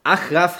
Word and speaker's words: Achraf: Enesiri, Akram, Akraf, Achraf Hakimi.